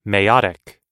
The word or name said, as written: meiotic